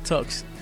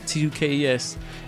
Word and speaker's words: Tukes: My last name is Tukes. T U K E S. It sounds like Tux, but it's Tukes though.